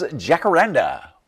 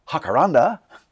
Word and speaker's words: Jacaranda: there is Jacaranda, or correctly pronounced, Jacaranda,